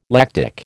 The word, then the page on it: lactic: American English